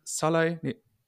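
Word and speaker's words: Sallai: Schalay, so ist es richtig ausgesprochen, nicht festhalten und Ritz Doğan schaltet dort am schnellsten, stoppt dann ab.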